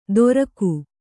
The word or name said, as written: ದೊರಕು